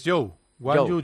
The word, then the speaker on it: Zhou Guanyu: Zhu Guanyu, ¿se dice así? Zhu Guanyu. – Es Zhou, Guanyu Zhou. Es como se dice. – Zhu Guanyu Zhou. – Es Zhou Guanyu. Es Zhou Guanyu. Es así.